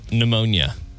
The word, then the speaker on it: pneumonia: Tennessee, it's pneumonia. That silent P on the front there.